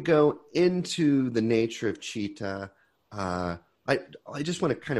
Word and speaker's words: citta: And this is commonly, citta is spelled C-I-T-T-A. It's pronounced citta.